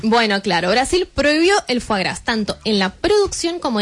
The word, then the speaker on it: foie gras: Brasil, acá tenemos una, Brasil prohibió el foie gras, que lo tuve que googlear, como se decía, porque se escribe foie gras. – Esa es la forma correcta, entonces. – Sí, díganlo foie.